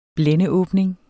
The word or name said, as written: blændeåbning